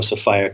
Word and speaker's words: Sophia: And you can call her Sophia if you like, but I call her Sophia because it rhymes with desire.